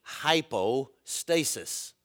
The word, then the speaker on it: hupostasis: The Greek word is hupostasis. Now, you actually know what that means because we would pronounce it in English hypostasis.